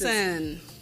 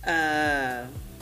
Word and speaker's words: BNXN: I am still going to call him that because what is BNXN? How do you pronounce Benson. It? Benson.